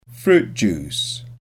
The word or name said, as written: fruit juice